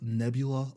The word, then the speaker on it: Nebula: And then the breadth and imagination of her work, it earned her 6 Nubella Awards, 7 Hugo Awards, and SFWA's Grand Master, along with... Yes? – Are you sure it's not pronounced Nebula Awards? – Whatever.